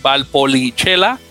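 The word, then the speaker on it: Valpolicella: También está un uruguayo, es Juan Manuel Rodríguez, que, pasa de Peñarol a, eh, Valpolichela, se pronuncia, sería. Eh, lo, bueno que toma esas clases de italiano en, la, secundaria. – sé, cómo pronunciarlo bien, que eso es lo bueno.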